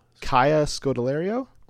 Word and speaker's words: Kaya Scodelario: Kaylee, uh, Kayla Scoldari- Scoldiardo, Kaya Scodelario. – Thank you. Wow, butchered that.